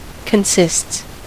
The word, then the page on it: consists: American English